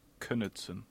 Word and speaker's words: Knudsen: And is it Knudsen, as in knee, or Knudsen, as in Knadel? – Well, I always said Knudsen with a silent K, but I am reliably informed it is the correct pronunciation, is indeed Knudsen.